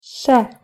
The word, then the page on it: ш: Russian